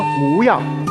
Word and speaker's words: 模样: 但是有两个字要纠正你，一个是牛犊的模样，跟我说模样。